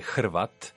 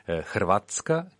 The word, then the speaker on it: Hrvat: Che è stata latinizzata, che all'inizio un H, che per la pronuncia italiana è un po' difficile, anzi sono tre consonanti insieme, HRV, Hrvatska, che significa Croazia, o Hrvat, che significa il croato, cioè Croazia in latino anche.